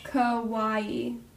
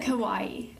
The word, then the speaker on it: Kauai: Kauai. – Okay. – You said it right, I think. – Kauai.